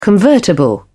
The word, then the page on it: convertible: British English